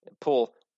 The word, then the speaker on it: Pul: Pul, king, one syllable.